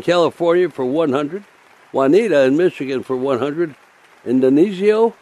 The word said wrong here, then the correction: Dionisio: how do we pronounce that? – Dionisio. – Dionisio in New York pledges $10.